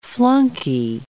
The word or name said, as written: flunkey